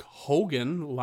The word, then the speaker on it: Hogan: Hulk Hogan li- it rhymes with Joe Rogan.